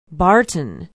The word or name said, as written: barton